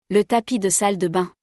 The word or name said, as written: Le tapis de salle de bains